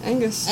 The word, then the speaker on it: Angus: No, I'm not saying that right. That name? – Yeah, Angus. – Angus? – Yeah. – Okay. I was like, I must be saying that E wrong.